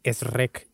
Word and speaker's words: Shrek: Creo como los españoles Shrek. Dicen, es rec y ya. Es rec. Así dice.